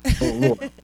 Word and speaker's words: O'Rourke: For those who are listening, I am Nicholas O'Rourke. For those that struggle to pronounce my last name, I recently heard someone say, "Yeah, like New York." O'Rourke.